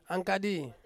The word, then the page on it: angkadi: Ansus